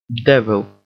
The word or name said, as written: devil